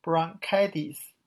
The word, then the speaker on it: bronchitis: 那这个单词呢，在发音的情况，大家注意一下，它这个 t 呀要浊化，读成 bronchitis。